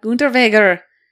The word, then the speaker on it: Unterweger: Jack Unterweger. I know him. I almost did an episode on him. – You pronounced his name way better than I did. You put the German emphasis.